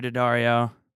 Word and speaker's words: Daddario: So we asked Morgan Nally and Alexander Daddario. – Daddario. – How's it go? – It's actually pronounced both ways.